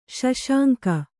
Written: ಸುಸಂಗ

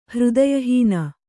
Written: ಹೃದಯಹೀನ

